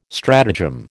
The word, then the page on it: stratagem: American English